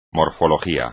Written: morfología

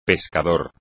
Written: pescador

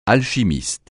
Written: alchimiste

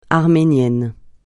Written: arménien

